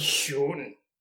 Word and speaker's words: schön: That's why, Shun, So I learned how to pronounce Shon and Shun because of the kid. The kid told me at Otako, don't mind. This is how you say it. Shun.